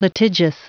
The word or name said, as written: litigious